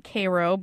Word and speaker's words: Cairo: We pronounce it Cairo, but it's spelled like Cairo, Egypt.